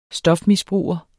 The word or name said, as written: stofmisbruger